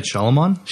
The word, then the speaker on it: Shyamalan: Night Shyamalan? – Shyamalan, okay, whatever.